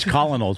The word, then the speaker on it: Colonel: And it's Colonel, too. It's not Colonel.